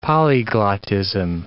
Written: polyglotism

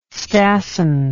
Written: stassen

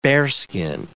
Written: bearskin

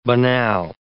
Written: banal

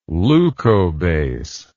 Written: leucobase

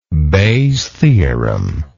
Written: Bayes theorem

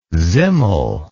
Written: simmel

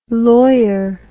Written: loir

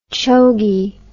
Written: chogie

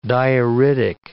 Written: dioritic

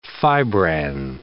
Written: fibranne

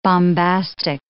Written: bombastic

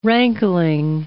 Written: rankling

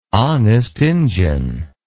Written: honest injun

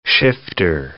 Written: shifter

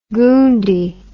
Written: goondie

